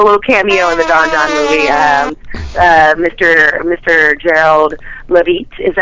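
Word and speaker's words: Don Jon: And now, is it Don Juan or Don John? I think it's Don Juan. – Oh. – I think the J is silent or something. – I think it's Don John. – Okay, I'm wrong.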